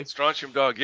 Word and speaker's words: Strontium Dog: Now let's talk about some of the other games that you casually mentioned in the interview, starting with your new one, Strontium Dog. – Oh, I said that correctly. – Strontium Dog, yeah.